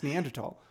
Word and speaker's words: Neanderthal: I was watching a Dutch documentary and they kept saying Neanderthal. – It is. – Is that how it is? – It's how you pronounce it. You pronounce Neanderthal.